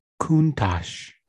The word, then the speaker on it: Countach: Countach. Is that how you say it? – Countach.